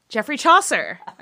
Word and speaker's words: Geoffrey Chaucer: Geoffrey Chaucer.